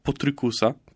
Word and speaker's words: Potrykus: Film w reżyserii niejakiego Joela Potrykusa, bo bardzo podoba mi się to nazwisko i wymawiam je sobie mocno po polsku, ale... – No, bo to jest polskie nazwisko.